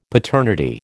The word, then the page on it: paternity: American English